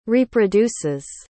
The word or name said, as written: reproduces